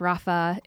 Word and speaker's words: Rapha: I think it's pronounced Rapha. It's R-A-P-H-A.